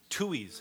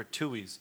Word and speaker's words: Tooheys: I saw the other day they're doing a casting call for hipsters, and let's see, the beer company Tooey's. Have you ever heard of Tooey's beer? – How do you spell it?